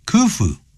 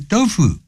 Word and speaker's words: Khufu: W-A-N-D-E-R-I-N-G wandering in that garden every morning 每天早上这样比较好一点免得吓人这样All right, 我们接着再往下看。The Egyptian ruler, 埃及的这个领导者也就是这个国王啦 Khufu, 这个人但是千万不要念成 Dofu, 那就不对了。Khufu, ordered the construction of the pyramid.